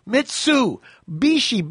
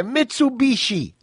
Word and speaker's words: Mitsubishi: It's MIT, MIT. SU, Mitsu. Bishi, B-I-S-H-I, Mitsubishi, not Mitsubishi.